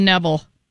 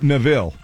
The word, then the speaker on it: Neville: So you don't say it like Aaron Neville. – Nope, not at all. – I've been saying it wrong then. – She's been driving. – It's okay. – She is like driving a Coupe Neville. A Cadillac Coupe Neville. – Yep, you got it.